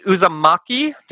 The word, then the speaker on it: Uzumaki: So next week we're going to be doing Uzumaki.